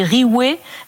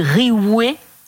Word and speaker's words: Rihouay: L'orthographe bretonne la plus classique, c'est Riouet, R-I-H-O-U-A-Y. – Ah, d'accord, une orthographe différente. – Ou avec un V à la place du H aussi, Riouet, avec un V, ça se prononce en fait exactement pareil.